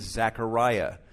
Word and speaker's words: Zechariah: His Hebrew name is Zechariah. That's how you would pronounce his name in the Hebrew.